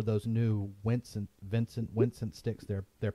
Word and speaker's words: Wincent: It might be called Vincent, but it's spelled Wincent, Vincent drumsticks.